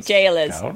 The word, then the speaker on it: gaolers: It's jailers. It's jailers. – Oh, gaolers. – It's jail. It's jail. It's pronounced jail.